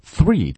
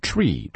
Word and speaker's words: three: 据说有人发三那个 three, T-H-R-E-E, 发不出来就会发成 tree, tree。